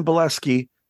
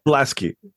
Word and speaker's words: Bielaski: Well, before we go into that, I'm speaking with Jonathan Bielaski. Did I say that right? – Jonathan Bielaski. Yep. – Bielaski.